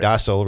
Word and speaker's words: docile: Docile is the correct English, American English pronunciation, by the way. – Yeah, it's really the way most people say it is British English. But either one is correct.